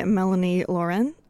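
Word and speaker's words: Mélanie Laurent: But Dylan Rhodes, played by Mark Ruffalo, and Alma Dre, played by Melanie Laurent. I'm pronouncing that the French way, so hopefully that's fine.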